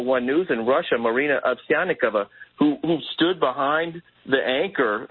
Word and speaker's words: Ovsyannikova: Ovsyannikova.